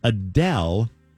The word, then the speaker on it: Adele: Adele is talking about how we're saying her name wrong all these years.